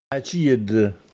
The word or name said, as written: Acjìdde